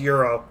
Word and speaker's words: gyro: No, a gyro. – It's pronounced euro, really, but I won't get politically correct with that, but gyro, euro.